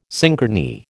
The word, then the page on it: synchrony: American English